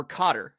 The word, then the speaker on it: Qatar: Race 11, six furlongs, the $2 million Qatar Racing, or Cotter, if you pronounce it like the welcome back, folks.